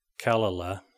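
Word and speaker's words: Kalala (קללה): In the Hebrew language, according to Strong's 7045, it's Q-L-A-L-A, pronounced Kalala, means a curse or cursing.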